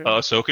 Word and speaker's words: Ahsoka: Ahsoka trailer.